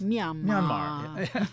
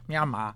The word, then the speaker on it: Myanmar: This next country we went to was Burma, also now called Myanmar. Or let's see, how would it be pronounced? – Myanmar. – Myanmar.